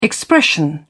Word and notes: expression: British English

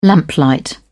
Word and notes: lamplight: British English

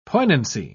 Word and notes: poignancy: British English

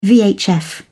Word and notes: vhf: British English

